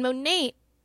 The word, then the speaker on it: Monat: And Monate is pronounced Monate, which we know because we've YouTubed it several times, but it is spelled M-O-N-A-T.